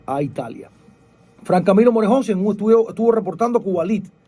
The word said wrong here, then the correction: Cubalite: Cubalite, Cubalite, siempre lo digo mal.